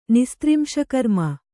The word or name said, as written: ನಿಸ್ತ್ರಿಂಶಕರ್ಮ